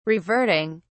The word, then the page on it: reverting: British English